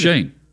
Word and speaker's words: Schoen: I didn't know if it was Schoen or Schoen, and it turns out it's Shane.